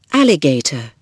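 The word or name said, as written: alligator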